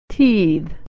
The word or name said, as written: teethe